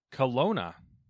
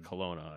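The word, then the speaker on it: Klonoa: Kelowna. – Alona. – Kelowna? Is the K silent? – No, I was just making a joke between the cologne name and the alone rhyme.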